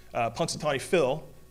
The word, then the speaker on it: Punxsutawney: And similar to Punxsutawney Phil, did I say that right? Thank you. Punxsutawney Phil, we have Mojave Max, and we do a emergence contest for Mojave Max.